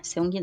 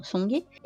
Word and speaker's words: Seung-gi: Que é um dos protagonistas junto com o Seung-gi, com o Sung-gi, né? Seung-gi não, Sung-gi.